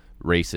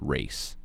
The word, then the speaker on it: Reyce: This isn't like race is literally a play on words. My name is Race. My name is Race. And it's not even spelled R-A-C-E. It's spelled R-E-Y-C-E.